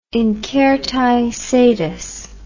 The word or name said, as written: incertae sedis